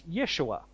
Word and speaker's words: Yeshua: Now, in Hebrew, that name is pronounced as Yeshua, or we might say Joshua, and it means the Lord saves.